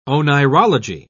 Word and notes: oneirology: British English

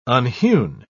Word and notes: unhewn: British English

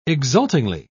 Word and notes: exultingly: British English

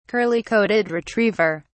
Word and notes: curly-coated retriever: British English

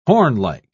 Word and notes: hornlike: British English

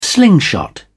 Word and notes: slingshot: British English

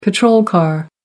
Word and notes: patrol car: American English

